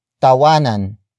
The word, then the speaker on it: tawanan: Tawanan, ang, problema. Tawanan, ang, problema. The first word is tawanan. Tawanan. Tawanan. Tawanan is spelled as T-A-W-A-N-A-N.